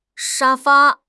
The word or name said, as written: sha1 fa1